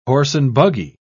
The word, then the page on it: horse-and-buggy: British English